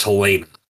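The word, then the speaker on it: Halyna: I've heard it pronounced Helena.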